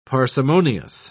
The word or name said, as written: parsimonious